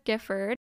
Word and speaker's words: Gifford: Okay, so my full name is Olivia Elizabeth Gifford. And then my maiden name is Cheek, like on your face, C-H-E-E-K. So yeah, and then Gifford is spelled G-I-F-F-O-R-D.